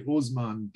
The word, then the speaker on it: Osman: We'll start off with Osimhen Deng, right? Am I saying that right? – I think you say it different every time you say it. I've been saying Osman Deng. – Okay, hey, Osman Deng.